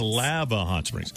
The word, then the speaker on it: Lava Hot Springs: And if, you know, if somebody says, we pronounce Lava Hot Springs, if it's a business owner, the chamber, the local resident, it's lava. Everything else is lava, except for Lava Hot Springs is Lava Hot Springs.